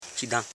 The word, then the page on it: chidan: chi.dan